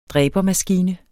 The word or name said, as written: dræbermaskine